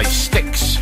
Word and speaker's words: Styx: Following that is Renegade by Styx. And it's not spelled S-T-I-C-K, it's S-T-Y-X. Best stop pronounced Styx.